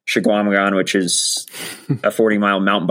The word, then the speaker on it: Chequamegon: Schwamigan, yeah.